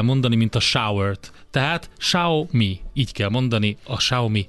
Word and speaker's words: Xiaomi: tehát ilyen Xiaomi, valahogy így mondják ki, ez baromi nehéz, tehát a nemzetközi standard, amit a cég szeretne, az a Xiaomi, így kell kiejteni.